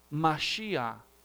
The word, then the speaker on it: Mashiach: La palabra ungido, o sea, untado, en hebreo se pronuncia Mashiach, de donde viene el término Mesías.